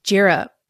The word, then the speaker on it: Jira: Jira. That was like my intro punchline for a while. It's like, hey, everybody, rhymes with Jira.